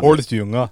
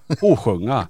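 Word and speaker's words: Hålsjunga: Holstjunga. – Nej, man säger med dialekt, Holsjunga. Holsjunga. – Kotunga. – Nej, Holsjunga. – Ja. – Så här lite Borås dialekt.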